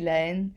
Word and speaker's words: La Haine: Vale, pues últimamente estuve escuchando mucho una de una película francesa que es, no sé cómo se pronuncia, Le Haine o La Haine, no sé. – Sí, La Haine.